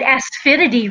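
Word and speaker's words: asafoetida: And you said asphidity right. Asphidity is a little tricky.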